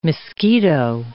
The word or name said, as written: Miskito Coast